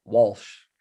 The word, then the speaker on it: Walsh: And he just said, you know, he's just noticed Jules pronouncing Welsh rather than Walsh.